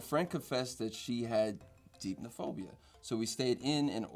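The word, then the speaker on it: Deipnophobia: Depnophobia? – Deepnophobia. – It was right there. – You were so close. – I said depnophobia? – Mm-hmm. – Yeah. – Yeah. – Long E. – Long E. – Yeah. Ah, hate those. Longies.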